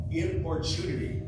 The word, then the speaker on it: importunity: But here we learn about the power of importunity. I had to look that up to see what it means. See how you pronounce it. Importunity. Importunity, importunate, okay?